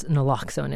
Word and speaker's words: naloxone: Mm, and it's naloxone, as you said. I mispronounced it a little earlier.